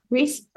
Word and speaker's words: Rhys: R-H-Y-S, right? Reese, right? – Reese.